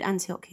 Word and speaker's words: Antioch: It is called Antioch Uni. Yes, it is. That is how you say it.